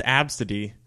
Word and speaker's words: Abcde: It's pronounced Abcde, but it's spelled A-B-C-D-E.